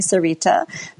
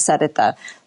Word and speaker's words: Sarita: I'm happy to help out with the pronunciation. I know it's challenging. Uh, Sarita yeah. is fine. A lot of people call me Sarita. The uh, Hindi pronunciation is Sarita.